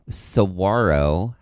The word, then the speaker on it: saguaro: My category is called Silent G because saguaro has a silent G in its name.